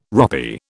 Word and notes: ropy: British English

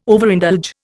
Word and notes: Overindulge: Indian

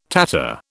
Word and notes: tatter: British English

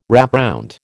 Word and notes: wrapround: American English

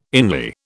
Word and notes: inly: British English

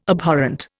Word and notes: Abhorrent: Indian

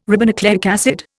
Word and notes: ribonucleic acid: Indian

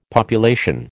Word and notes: population: American English

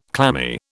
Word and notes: clammy: British English

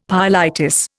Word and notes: pyelitis: Indian

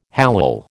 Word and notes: hallal: American English

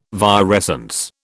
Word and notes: virescence: British English

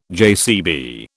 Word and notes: JCB: British English

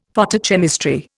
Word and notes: Photochemistry: Indian

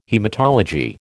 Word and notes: haematology: American English

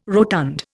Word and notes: rotund: Indian

